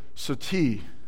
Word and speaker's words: sati: Of course, he came from a nation that up until recent times and even currently in some rural parts practices widow burning, the practice of sati, S-U-T-I, or sorry, S-A-T-I, and it's one of their A's is pronounced with the U, where the widow's life is over when her husband dies.